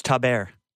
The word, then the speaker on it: Taber: Catherine Tabor, apologies if I butchered that, as Padme Amidala. Yeah, dude, it's Taber. Taber. Taber.